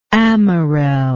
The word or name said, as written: amarelle